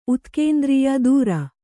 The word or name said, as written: ಧುರ